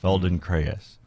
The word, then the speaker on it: Feldenkrais: It's F-E-L-D-E-N. – Hey, I had that right. That's good. – K-R-A-I-S. Feldenkrais. – Oh, Cray. Right. A. – Silent letters.